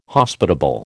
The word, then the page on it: Hospitable: American English